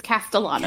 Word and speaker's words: Castellano: And now their leader is Paul Castellano. – Right. Castellano. – I stumbled all over that because it's two L's and my Spanish came out, and I just say Castellano. – Yeah, it's Castellano.